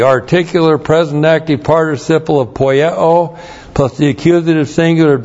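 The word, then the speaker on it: Poieo (ποιέω): Start out with the verb poieo. P-O-I-E long O. Poieo.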